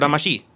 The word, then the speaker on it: Damacy: Then there's games like Katamari Damacy, or Damashi, if you prefer the Japanese pronunciation.